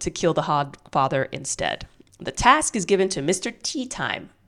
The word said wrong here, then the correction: Teatime: which is a mispronunciation. It's Teatame.